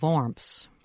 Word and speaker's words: Worms: Well, first let me correct your pronunciation, if I may. The city is called Worms on the Rhine River.